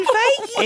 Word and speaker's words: Feige: Kevin Feige.